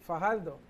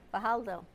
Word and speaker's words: Fajardo: How do you say the name of the city? – Fajardo. – Fajardo.